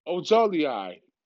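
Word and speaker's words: Ojulari: The Miami Dolphins select University of Georgia defensive end Akinzi Arabi Ojibale. Ojolei. Excuse me, y'all. AZ Ojolei.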